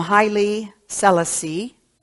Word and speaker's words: Haile Selassie: From Haile Selassie, and I looked that up because I've always pronounced it incorrectly. So I looked it up. And that was apparently the correct pronunciation as emperor of Ethiopia for a time.